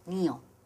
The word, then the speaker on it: Neo: Sí, se escribe Neo, pero se pronuncia con la I, como en inglés. Neo.